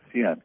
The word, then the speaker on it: Xian: No, X-I-A-N-G. – Oh, Xian, but, Xian, like Xian. X-I-A-N is Xian. – Oh, okay.